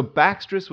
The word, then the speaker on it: Bactria: Okay, so Baxtris was a Persian way of pronouncing the name. Greek speakers might have called it Bactriani, and English speakers today we use that term Bactria.